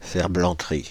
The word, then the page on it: ferblanterie: Paris; [fɛʁ.blɑ̃.tʁi]